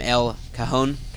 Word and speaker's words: El Cajon: He's a 6'8", 210-pound forward from El Cajon, California.